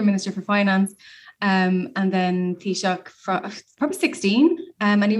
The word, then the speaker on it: Taoiseach: I'm going to try and pronounce this. Taoiseach. – It's a Taoiseach. – Taoiseach. – Taoiseach. That's right. That's how to say it, isn't it? – Yeah.